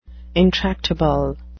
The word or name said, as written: intractable